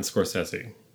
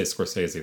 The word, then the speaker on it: Scorsese: And for me, Scorsese, I'm going to say his name both ways, all the ways, the whole time. I can't be consistent about Scorsese. I'm probably going to say Scorsese. That's what I grew up saying. Scorsese, I think, is how you actually pronounce his name, but I can't do it.